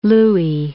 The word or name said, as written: Louis